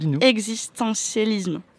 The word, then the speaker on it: existentialisme: Parce qu'il appartenait les deux dans la vague de, alors là, je ne sais pas prononcer ce mot, existentialisme. – Tu l'as dit parfaitement.